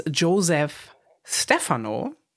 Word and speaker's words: Joseph Stefano: Das Drehbuch hat geschrieben ein Mann namens Joseph Stefano.